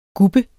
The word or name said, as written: gubbe